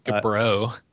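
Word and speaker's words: Gibeau: But So there was a story that came out that, is it Frank Gabo? Is that how you say his name, I guess? President of... – Frank Gabro.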